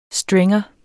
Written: stringer

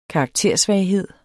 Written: karaktersvaghed

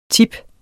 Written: tipper

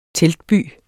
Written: teltby

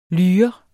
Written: lyre

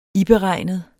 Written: iberegnet

